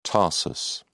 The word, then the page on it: tarsus: British English